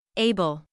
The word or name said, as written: able